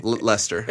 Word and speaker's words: Leicester: How do you say L-E-I-C-E-S-T-E-R? – That's Leicester. – Leicester? – Yeah. – No, it's not. – Yeah, that's the British. Yeah, Leicester. Leicester Square.